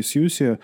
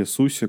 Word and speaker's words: SUSE: А связанные с компанией Сьюси, а, или Суси, как правильно, Лех, произносить, ты знаешь, или Сьюзи? – Вот, наверное, зависит от акцента. Мне что-то ближе к Сузи, на английский манер. – Сьюзи.